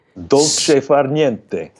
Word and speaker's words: Dolce far niente: Dolce far niente. – Dolce far niente. – Okay, yes. – Dolce far niente. And you have to wave your hand. There you go. Dolce far niente.